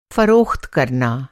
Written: फ़रोख़्त

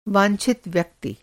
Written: वांछित

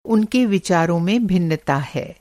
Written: भिन्नता

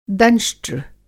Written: दंष्ट्र

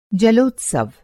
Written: जलोत्सव

